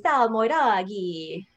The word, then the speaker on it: Salmoiraghi: Well, I don't even say it right. I think the Italians say Salmoiraghi.